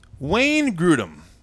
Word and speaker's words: Wayne Grudem: Wayne Grudem. I don't even know if I said his name correctly. – No, that was correct.